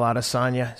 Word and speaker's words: Adesanya: I look forward to seeing all those fights, and I look forward to seeing Adesanya. Am I pronouncing his name properly? – Yeah, Israel Adesanya is fighting Jan Blachowicz.